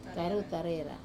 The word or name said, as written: karutarera